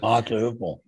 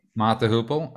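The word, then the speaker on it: Mott the Hoople: Mata Hoople. – Mata Hoople.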